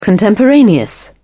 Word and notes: contemporaneous: British English

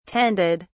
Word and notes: tended: British English

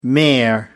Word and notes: mayor: American English

